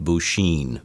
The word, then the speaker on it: Buchine: B-U-C-H-I-N-E. – But pronounced Buchin, very much. – Buchin, yep. – Okay. – It's pronounced Buchin.